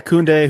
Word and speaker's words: Koundé: Koundé, is that his name? I can't pronounce his name. – Yeah, Koundé, who may or may not end up at Chelsea.